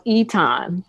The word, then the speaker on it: Etan: Yeah, so it's really pronounced Etan, okay. But there's an accent that we don't have on in the American alphabet.